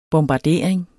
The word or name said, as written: bombardering